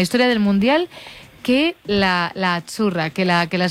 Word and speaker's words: Azzurra: Azzurra. Mira, ves, es que azzurra. Sí, porque pizza, la Z no es la Z como decimos en catalán tan sonora, no. Es azzurra.